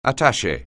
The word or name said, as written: attaché